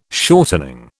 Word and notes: shortening: British English

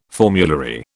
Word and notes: formulary: British English

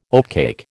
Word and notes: oatcake: American English

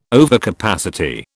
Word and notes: overcapacity: British English

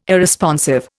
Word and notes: Irresponsive: Indian

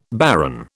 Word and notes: baron: British English

